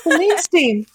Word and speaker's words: Celine Steen: Celine Steen. Comes out July 6th. – Cool. – I love that her name rhymes, if yeah. I'm saying it correctly. Celine Steen.